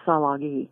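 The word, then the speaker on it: Tsalagi: And my grandmother was part and or probably mostly full-blooded Salagi, or what this country calls Cherokee, but it's actually pronounced Salagi.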